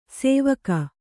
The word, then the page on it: ಸೇವಕ: sēvaka